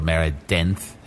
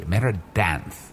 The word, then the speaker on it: Meridanth: sound like Meridanth or Meridenth, but it is spelled with an A, Meridanth.